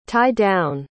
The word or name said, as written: tie-down